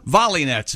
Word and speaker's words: Volynets: Volley Nets, V O L Y N E T S. – Wow. – She says, though, for the convenience of English speakers, she does pronounce it Volley Nets, but she is from, she has Ukrainian parents, born in the United States.